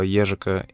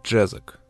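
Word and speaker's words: Ježek: Фамилия нашего сегодняшнего героя принято произносить в интернете как Джезак. Но это не совсем правильно, потому что фамилия это чешская, и произносится она правильно как «ежик».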